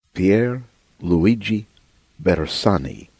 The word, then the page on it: Pier Luigi Bersani: American English